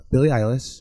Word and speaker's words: Billie Eilish: You have Billie Eilish.